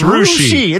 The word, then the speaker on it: Russia: It's Roushi. It's Russia. It's spelled like Russia.